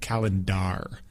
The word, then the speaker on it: calendar: And in English, it's calendar, like it's spelled with the AR at the end, even though it sounds like ER calendar.